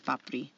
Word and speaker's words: papri: Um, the first word is, I think, P A P, either D I or R I. It's pronounced papri.